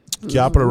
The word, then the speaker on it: Chiappa: Chiappa Rhino. I'm sorry, it's Chiappa. That's how you say it. – What is that? – Yeah, I think I'm pretty sure that's how it's said.